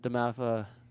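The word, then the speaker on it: DeMatha: So Imhotep and Damatha, I said that right? – Yep.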